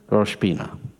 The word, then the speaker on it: Rosh Pinah: On the phone, how do you say that again? Rosh Pinah.